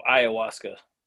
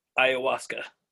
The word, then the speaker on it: ayahuasca: Oh, ayahuasca. The H is silent. Ayahuasca.